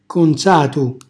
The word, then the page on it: consatu: calabrese